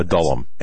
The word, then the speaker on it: Adullam: Adullam. – A-D-U-L-L-A-M.